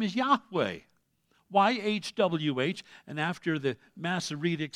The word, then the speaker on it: Yahweh: It was Y-H-W-H in the consonantal language they had. But when vowel pointing became a reality, done, by the way, by Jewish scholars that knew exactly how to pronounce the name, they vowel pointed it in such a way it sounded like Yahweh.